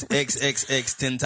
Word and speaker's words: XXXTentacion: I called it XXXTentacion. – You did. – I thought his name was Extension. – No. It's XXXTentacion.